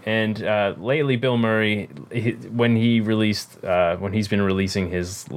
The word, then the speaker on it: Bilmuri: A band that I really like, named Bill Murray, which is funny all on its own, but it's spelled completely different. B-I-L-M-U-R-I.